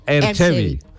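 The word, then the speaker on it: Erg Chebbi: Ajá, Erchevi se pronuncia. Erchevi.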